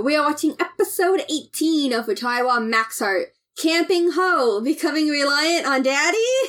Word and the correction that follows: Hoy: No, hold on, you said it wrong. I Did I? – Yeah, it's not... Yours isn't Camping Ho, yours is Camping Hoy.